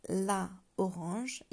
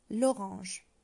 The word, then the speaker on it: l'orange: Well, we wouldn't say la orange, even though it's a feminine and singular, but we would say l'orange.